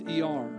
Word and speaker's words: Iyar: Perhaps some of this has to do with the current Hebrew month that we're in this month of I-Y-A-R, pronounced E-R.